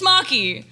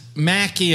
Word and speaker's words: Maki: And actually being like, it's Maki. So, I don't know. – I mean, to be honest, Maki is also a very Midwestern way of me pronouncing it too.